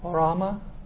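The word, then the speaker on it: Orama (ὅραμα): Orama.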